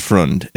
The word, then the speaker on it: Freund: That's what's crazy about the history of this movie, the more you investigate it, is that the cinematographer is Carl, I think it's pronounced Freund. He's a German name. It's Freund.